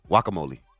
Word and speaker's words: guacamole: If you want to say it properly, don't put the hard G on there. Like a W, guacamole.